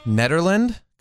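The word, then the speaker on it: Nederland: Netherland, Colorado? – Yeah. – Is that how you yeah. – say it? Okay.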